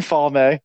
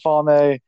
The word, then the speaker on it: Falme: So he's in Falme, um, which is pronounced a bunch of different ways. I pronounce it Falme. I've heard it pronounced Falm, Falme, uh, a bunch of different places. Flame. I don't know. I, I say Falme, but whatever.